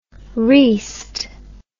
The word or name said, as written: reast